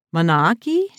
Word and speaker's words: manaaki: I mean, it took me by surprise when I came to New Zealand, the incredible generosity, and I think I'm pronouncing this Maori word correctly, manaaki? – Yes, you are. Correct.